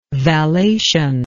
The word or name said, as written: vallation